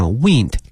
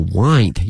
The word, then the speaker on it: wind: 后面这个词 wind 它这个拼写啊像风一样哈 wind 但是呢它的发音是这个 wind 因为它跟风没有关系了它是一个动词表示说转来转去的意思哈表示这个路啊弯弯曲曲的感觉所以呢这些架子呢 wind up and around the hall and continue around the building to the ceiling，一直呢通往图书馆的顶层，叫这个 ceiling。